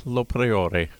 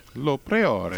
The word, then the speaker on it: Lo Priore: I think you got the pretty Lo Priore. – Lo Priore. Eh? – Yeah, very nice. – It's Italian, done. So you just pronounce everything.